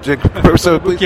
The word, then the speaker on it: Kotobukiya: And it's Kotobukiya. I never say it right. – Kotobukiya, yes, that's okay. Correct.